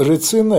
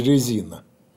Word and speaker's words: рецина: Ну, рецина – это нынешнее греческое произношение. В Древней Греции произносили резина.